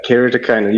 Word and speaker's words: keratoconus: Yeah, keratoconus. You did perfectly today. There's no problem with the pronunciation.